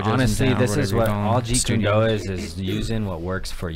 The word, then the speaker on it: Jeet Kune Do: But his style, he's a Jeet Kune Do.